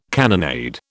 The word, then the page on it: cannonade: British English